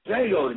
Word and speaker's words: Django: Django, the D is Silent.